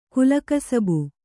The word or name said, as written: ಕುಲಕಸಬು